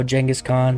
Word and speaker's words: Genghis Khan: Also, Genghis Khan pronounced that way because I was told it is closer to how it would have pronounced back then. It's been anglicized into a normal pronunciation.